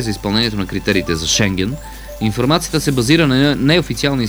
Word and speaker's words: Шенген: Одобриха докладите на България за Шенген. Ударението е правилно, не се мислете, че нещо се шегувам.